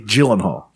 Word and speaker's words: Gyllenhaal: With Jake Gyllenhaal. It's a hard G, soft J.